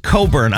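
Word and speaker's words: Cockburn: Yeah, it's pronounced Coburn. I know how they spell it. Coburn.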